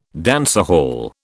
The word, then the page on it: dancehall: British English